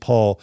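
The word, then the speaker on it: Poll: It's spelled Pole, but it's pronounced Paul. – Yeah, P-O-L-O.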